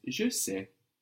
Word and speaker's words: je sais: I know in French is je sais. Je sais, I know. – Je sais. – This is spelled S-A-I-S.